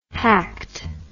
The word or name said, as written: hacked